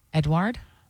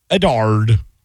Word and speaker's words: Edouard: E-D-O-U-A-R-D. Edouard? – Edward? – I get, well, there's an O before the U. Edouard. Edouard!